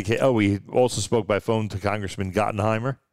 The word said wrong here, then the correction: Gottheimer: Congressman Gottheimer, I should say it properly.